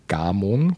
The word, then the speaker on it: Gamon: Gamon, glaube ich, wird sie ausgesprochen, so mit einem langen A.